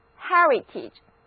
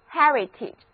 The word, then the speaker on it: heritage: 注意字母 a 和字母 i 的发音都是短而急促的 e，和我一起来念念吧， heritage， heritage。